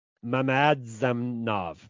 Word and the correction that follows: Mamadzadanov: Mamadzadanov. – Oh, yes. There's an extra syllable on there that I missed.